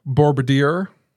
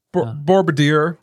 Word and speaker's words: Borobudur: And I believe it's pronounced Bourbadir. I'm not hearing one of you guys correct me, so... – Can I see? – You act like I know what you're doing. I don't get it. – Bourbadir. – Bourbadir, I think is how it's pronounced. Okay. Bourbadir. – I agree with you.